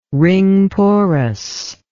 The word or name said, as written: ring-porous